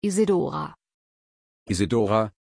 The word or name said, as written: isidora